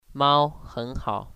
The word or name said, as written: ao